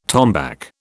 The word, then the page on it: tombac: British English